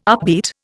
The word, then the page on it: upbeat: Indian